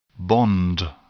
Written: bond

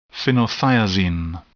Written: phenothiazine

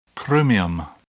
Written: chromium

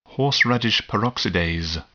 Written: horseradish peroxidase

